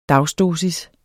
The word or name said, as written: dagsdosis